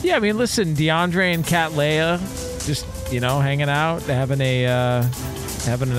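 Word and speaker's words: Kat Leia: Is that how you pronounce it? – Yeah, K-A-T. Yeah, Cat Leia.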